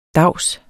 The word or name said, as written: davs